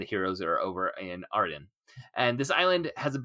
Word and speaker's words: Auradon: And in this world, Belle and Beast are married, and they rule a kingdom named Auradon. Am I saying that right? I can't remember. – Auradon. – Auradon. Okay. How they said it in the film.